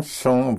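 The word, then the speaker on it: sont: for the third person plural, for they, you don't have the silent ENT, but a one syllable ONT. En son vont font.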